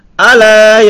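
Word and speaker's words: Ala (ألا): Ayam kisah yang bacanya bukan Allah yasjudu, tapi apa bacanya? Ala yasjudu lillahi alladhi ukhrijul khaba'a fis samawati wal ard. Ala. Alanya enggak pakai tasdid.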